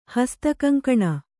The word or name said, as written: ಹಸ್ತಕಂಕಣ